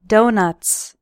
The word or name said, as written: donuts